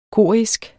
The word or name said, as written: korisk